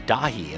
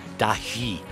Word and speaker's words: Dahee: That's Dahi D A H E E all caps stylized in English. Dahi I guess would be the proper pronunciation.